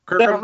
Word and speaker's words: Kirkham: Your last name, Kirkham or Kirkham? – Kirkham? Right, my friend.